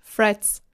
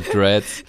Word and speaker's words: Threads: So, wie spricht man das als Deutsch? Deutschsprachiger Mensch aus Threads. – Ich muss immer, also wie Fred, Threads. – Threads oder Dreads.